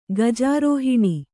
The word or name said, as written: ಗಜಾರೋಹಿಣಿ